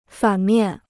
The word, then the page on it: 反面: fǎn miàn